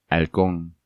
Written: Halcon

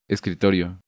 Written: Escritorio

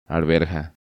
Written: Arverja

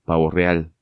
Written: Pavo real

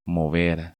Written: Mover

